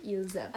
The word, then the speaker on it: ils aiment: So, like, if you say, like, they like, you would say ils aiment, and even though the pronoun ILS, you wouldn't normally pronounce the S, you just say il. When it's followed by a vowel, you would say ils aiment.